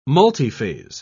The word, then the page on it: multiphase: British English